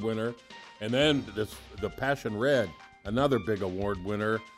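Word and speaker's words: Pasión: It's from Murviedro Passion, P A S I O N, with an uh, emphasis on the O, and so it's passion.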